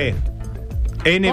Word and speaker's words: Nvidia: Sin la E. Nvidia. – ¿Cómo no por NVIDIA? O sea, la, se pronuncia envidia, pero va sin la L escrito.